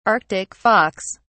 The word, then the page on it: arctic fox: British English